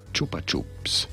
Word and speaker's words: Chupa Chups: Have a listen to how it's pronounced. – Chupa Chups. – Sorry? – Chupa Chups. – Chupa Chups. That's, Chupa Chups. – That's how they say it in Spain and a lot of other countries around the world.